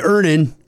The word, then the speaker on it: Earnin: Earnin. – Earnin. – E-A-R-N-I-N is pronounced Earnin. I don't know how else you would pronounce it, but they really want it clear that that's how to pronounce it.